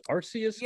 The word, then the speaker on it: Arceus: Was the whatchamacallit, Pokemon Legends Arceus? – Yes.